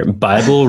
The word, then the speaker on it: Bible: Bible rhymes with libel.